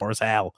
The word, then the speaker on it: Barzal: They don't like how I say all. Like I've got two L's on you. It's Matt Barzal.